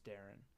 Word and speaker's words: Deron: I could be wrong, but I think I've heard people try to pronounce it Deron, and I'm almost 99% sure it's Deron. So if you're one of those people saying Deron, stop.